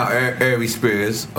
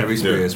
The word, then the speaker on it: Ari Spears: So we see just now, Ari Spears. I said his name right? – Yeah, Ari Spears.